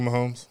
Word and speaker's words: Mahomes: Patrick Mahomes.